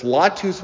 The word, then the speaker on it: latus: Again, I'm not a Latin scholar, but this latus with a long A, and that means latus to carry or to bear.